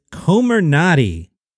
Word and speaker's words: Comirnaty: That name is Comirnaty. Comirnaty. I'll spell it for you. C-O-M-I-R-N-A-T-Y.